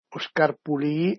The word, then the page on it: ol scarpulìi: Dialetto di Albosaggia